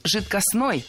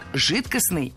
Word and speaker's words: жидкостный: Итак, прозвучали варианты жидкостный и жидкостной. А какой из них верный? Вариант жидкостный является литературной нормой. Ударение исходного существительного «жидкость» в нем сохраняется, но окончание должно быть «ый». Жидкость – жидкостный.